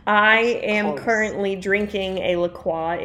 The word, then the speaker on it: LaCroix: The passion fruit LaCroix is pretty damn good. – See, I actually pronounce it LaCroix. – LaCroix, because it's French, but I know everyone calls it LaCroix.